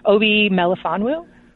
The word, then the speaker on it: Obi Melifonwu: Obi Melifonwu? – That's how I would pronounce it. I'm going to say you're right.